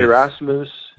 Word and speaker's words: Erasmus: I think David Hart is the Erasmus, did I say that right? – Yes. – Erasmus, mm-hmm, for today, because he's translated the New Testament.